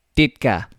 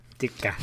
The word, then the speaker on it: Ditka: pronounced Ditka. – Ditka.